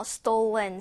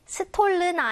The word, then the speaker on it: stolen: stolen 사용하시면 되셨습니다. 자, stolen부터 먼저 발음하는 연습해보죠. stolen. 스톨른 아니고 stolen, 비로 stolen.